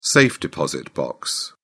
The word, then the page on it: safe-deposit box: British English